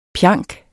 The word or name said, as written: pjanket